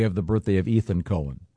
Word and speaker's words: Cohen: Leonard Cohen. – Cohen. – This that's confusing because not when it's spelled C-O-H-E-N. – Yeah. It's definitely Cohen.